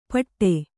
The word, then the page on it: ಪಟ್ಟೆ: Kannada